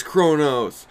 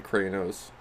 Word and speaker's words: Kronos: I think I said Kranos.